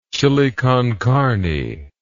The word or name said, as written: chile con carne